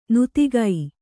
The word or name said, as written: ನೀತಿಕೆ